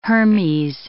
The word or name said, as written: Hermes